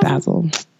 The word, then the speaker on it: Basel: Is that how you say it? Basel? – I thought it was Basel. – Basel? Art Basel. I'm not cultured. – Basel. You gotta say it like that.